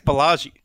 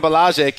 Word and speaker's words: Ballage: Do I got that right? – I think it's Ballage. – I had no chance. B-A-L-L-A-G. Ice Cube, do you know the pronunciation on that one? – It's what you said, man.